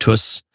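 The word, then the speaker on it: Töss: We had an umlaut over the O, so we're actually pronounced Tuss. Tuss.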